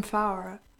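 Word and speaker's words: Fauré: From Faure. – From Faure? Faure, sorry. My pronunciation is terrible.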